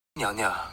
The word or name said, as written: niangniang